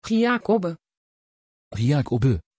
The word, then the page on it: giacobbe: Dutch